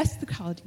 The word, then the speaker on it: eschatology: But if you feel that it's appropriate and would fit in a framework of eschatology, did I say that right? I would eschatology? – Like to, eschatology, yes.